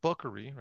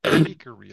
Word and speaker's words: bakery: it's not buckery, right? It's not a short U sound. It's bakery, a long A sound.